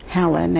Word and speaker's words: Helen: Helen, H-E-L-E-N, last name C-O-L-I-N. I was born in Lodz, L-O-D-Z, Poland.